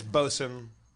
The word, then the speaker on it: boatswain: Like boatswain is bosun. A gun soul is a gunnel or whatever, you know. They shorten things.